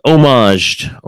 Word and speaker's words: homage: Yeah, they had gone about 500 years without without kings there, and so he kind of like homage. That starts with H, even though it's a silent H, but it's there.